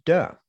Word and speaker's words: De: Tao and De, as it's pronounced.